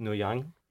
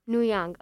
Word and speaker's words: Nguyen: I was always under the impression that that last name is pronounced Nguyen. – Nguyen, okay. Fat Nguyen. – It's like one of the most common Vietnamese last names, but I don't know a lot about it, so I don't want to.